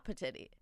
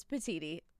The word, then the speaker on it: Petiti: It is spelt Petiti. It is not Petiti. It is not Petiti. It is not Petits. Petits. It is Petiti.